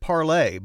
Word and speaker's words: Parler: Its founders from Denver, Colorado, back, 2 years ago, called it Parlay, but that's not how a lot of others are saying it these days. – Yeah, I think people are like, get that French nonsense out of here. We're going to call it Parler, and that's what it is. I think the users have kind of overtaken that. – Taken it over. – Pronouncing it that way.